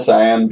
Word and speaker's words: Siam: Punch up to, I cannot pronounce this, I'm going to spell it, at Royal S-I-A-M Beauty. She says, best SEO card. Best SEO podcast ever. – Siam. Royal Siam Beauty. – Siam? – Yeah. – Okay, Royal Siam Beauty. Royal Siam Beauty.